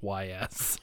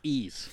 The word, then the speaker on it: Ys: I just thought it was YS. – No, it's YS. YS. – The Legend of YS. – Yeah, it says right here, pronounce E's.